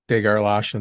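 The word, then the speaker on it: de Gerlache: I think a lot of, you know, there's some debate about whether it's a hard G or a soft G. And I used to say Degarlash, but the family themselves have specified that. And even a lot of Belgians pronounce it Degarlash, but the family themselves informed me that it was a soft G.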